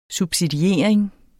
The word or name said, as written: subsidiering